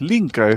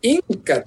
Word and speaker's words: Linka: Casi como Laika, pero no, es Linka. Esta es la rusa que controlaba el viento en el Capitán Planeta, y los Planeteers. Esta vez. – Y siempre lo pronunciaba mal el Capitán Planeta. Inca, que le decía.